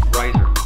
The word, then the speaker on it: Reiser: First, tell me how to pronounce your name. – Reiser. – Reiser. – Yeah, it's like an extra syllable in there, right? – It does have a little bit of an extra syllable. It's an old German name they like to throw around those ERs.